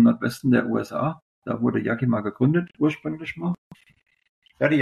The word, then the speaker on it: Yakima: Das war die Firma Yakima, ist, glaube ich, richtig ausgesprochen, oder? – Yakima, ja.